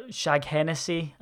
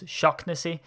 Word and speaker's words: Shaughnessy: Shaughnessy or Shaghennessy, as it's said sometimes, is Shocknessy, in actual fact, because we do get that from time to time.